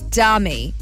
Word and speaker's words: Demi: And people were calling out Demi, like D-E-H-M-E-E, if you mm-hmm. say it phonetically. And she quickly set the record straight by saying it's Dummy, D-U-H-M-E-E, phonetically said.